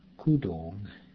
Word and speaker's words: kudong: For example, the double U in the second part of the word for pants, kudong, makes you naturally accent the second syllable, the correct pronunciation.